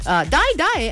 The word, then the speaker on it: Dai Dai: Die Die actually isn't, it's spelled D-A-I-D-A-I, okay?